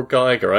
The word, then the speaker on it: Giger: Well, it could be pronounced Giger or Geiger. I always pronounced it Geiger because of the long I after the single letter. His artwork... – How does he spell it? G-E-I-G-E-R? – G-I-G-E-R. – Oh, G-I-G-E-R.